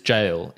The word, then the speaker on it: gaol: The first one to come up is jail. And you're saying, what? Jail? How is that Aussie slang? Well, yes, when it's spelled G-A-O-L.